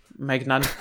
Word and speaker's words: Maignan: Und dann wurde ein Tweet von EA gefaked, wo drin steht, however, der Menon, oder wie er heißt, Menon, keine Ahnung, wie man diesen französischen Torwart von Mailand aussprechen sollte. – Maignan. – Maignan, ja, genau. Genau das wird die Aussprache sein.